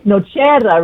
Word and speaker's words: Nocero: No, I had a wonderful interview with an Italian gentleman, Filippo Potaggio, the other day, and it's Nocera, really. You know, my Italian roots come screaming out.